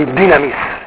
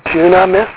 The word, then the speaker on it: dynamis: modern pronunciation would be dynamis. Erasmian dunamis? Yeah.